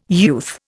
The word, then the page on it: youth: Indian